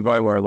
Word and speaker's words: Weimar: And I guess the contrast between Berlin and so Weimar, am I saying that right? That's a city. – Actually, Weimar, Lai.